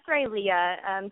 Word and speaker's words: Rileah: Yes, it's pronounced Rileah. People close to me call me Riley, though, for short, because two syllables, so much easier than three, apparently.